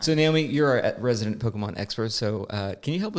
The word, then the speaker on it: Pokémon: In fact, I'm probably saying Pokemon wrong. Isn't it more like technically it's Pokemon or whatever? It's more of that accent on the E, especially when you watch the anime, so they show it.